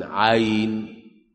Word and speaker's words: عين: ما هي لازم تنطق، أصل هي الياء نص العين يا أخوان، عين عين، هي العين كده، العين كده، العين كده، عين وياء ونون، حرف ثلاثي. اقول لك اكتب عين، اكتب اسمها، تكتب عين ويه ونون، فعين حرف ثلاثي وسط حرف مد، فتقول عين صا كده، فانت احنا بننطقها عشان هي حرف مد اصلا.